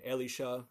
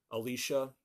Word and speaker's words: Elisha: You might also call him Elisha or Elisha, if you use a different English pronunciation there.